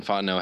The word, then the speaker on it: Fontenot: But it's also really cool just because Terrio and Fontenot had such a history of playing across from each other. You know, their names rhyme. They're both from they're both silent T's and all that.